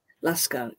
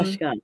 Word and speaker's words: Lashkar: And it seems like you consider it very important to tell those stories, because one of your books, how do you pronounce it? Lashkar? – Lashkar, yes. – In Urdu, we say... In Urdu, we say Lashkar. So, Lashkar, yes.